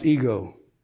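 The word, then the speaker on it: ego (ἐγώ): There's a Greek word that's always translated as a pronoun or personal pronoun, and it's spelled epsilon gamma omega, and it's pronounced ego.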